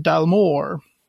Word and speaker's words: Dalmore: Eh, destilleriet som jag nu flera gånger säkert har kallat för Dalmore, vilket jag är livrädd för att säga fel, det uttalas egentligen Dalmore.